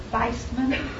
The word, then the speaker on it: Weizmann: Kahn, Weizmann, Weissmann, German pronunciation, had invented dynamite, TNT, offered it to the British, and they used it to win the war.